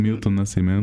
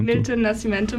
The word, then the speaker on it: Milton Nascimento: Als nächstes Lied, hast du uns ein Lied von, ich kann es leider nicht so gut aussprechen, Milton Nascimento. – Milton Nascimento mitgebracht.